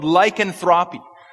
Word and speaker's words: lycanthropy: It's called, and I don't know how to pronounce this name, but it's called lycanthropy. Lycanthropy. Somebody just confirmed my pronunciation. Thank you, brother.